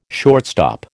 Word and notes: shortstop: American English

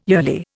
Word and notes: yearly: Indian

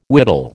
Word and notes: whittle: American English

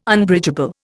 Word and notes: Unbridgeable: Indian

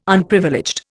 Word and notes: Unprivileged: Indian

